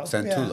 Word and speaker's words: Santullo: Santullo, yeah.